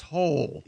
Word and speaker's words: Hole: But it's pronounced Hole. – Yeah, Ole. – Detective A. Hole. Yeah. – It's spelled H-O-L-E. Detective Hole.